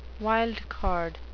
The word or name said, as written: wild card